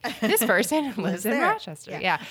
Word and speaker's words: Rochester: Am I saying that right? – Rochester. Rochester. Yeah. – But if you say it like slow, it doesn't sound right at all.